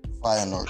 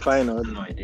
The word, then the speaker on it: Feyenoord: Feyenoord. – Feyenoord. Yes, Feyenoord.